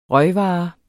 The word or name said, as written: røgvare